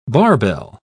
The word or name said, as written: barbell